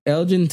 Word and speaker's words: Elgin: Say it, but I don't think you said that correctly. – I'll say Elgin. – Elgin, there you go. Elgin, Texas, probably.